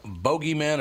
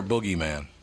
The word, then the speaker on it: bogeyman: You pronounce it bogeyman or boogeyman? – I would say boogeyman. – Yeah, that's what everybody says, boogeyman. – Boogeyman is the, like, British version. – Yeah, it's only the Brits say bogeyman? – Pretty much.